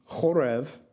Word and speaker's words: chorev: The word for drought is chorev, and the word for ruins is harav.